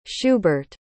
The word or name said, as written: schubert